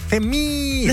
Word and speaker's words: Femi: Er det F3Me? – Femi. – Femi.